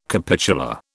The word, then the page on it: capitular: British English